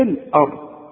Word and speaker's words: الأرض: احنا اتفقنا ان الهمزه بتاعه القران دائما مرققه فما نقولش في الاه في الارض لا في الاه في فرق اهو في النطق ما تقولش في الآ في ال شوف حتى اللام طلعت منك مفخمة لا اللام مرققة في الأرض